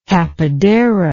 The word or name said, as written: tapadera